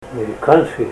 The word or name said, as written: mirikanski